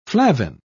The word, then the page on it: flavin: British English